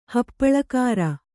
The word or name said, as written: ಹಪ್ಪಳಖಾರ